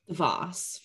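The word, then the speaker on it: DeVos: Like, I didn't know about them before, and I didn't know about the, how do you say it again? Davos? – The Voss family.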